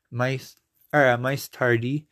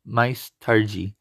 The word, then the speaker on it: mais tarde: Basically, instead of pronouncing your I as my, as the I, you make, you pronounce the first word, mice, as mice, or you make the E have an E sound. So instead of mice tardi, it would be mais tarde.